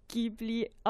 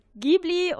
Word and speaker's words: Ghibli: Und ähm, im Prinzip, die Japaner, die sprechen es Ghibli aus. genau. und Miyazaki und die ganzen Mitglieder von äh, Studio Ghibli auch. Aber ich zum Beispiel sage Ghibli und Ghibli ist an sich auch die richtige Aussprache des äh, arabischen Wortes.